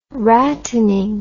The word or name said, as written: rattening